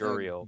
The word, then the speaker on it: Gurriel: I think it's Gurriel.